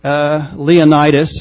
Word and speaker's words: Leonidas: Leonidas is the way you'd pronounce it. They always said Leonidas, named after the Greek general who stood in the gap with the 300.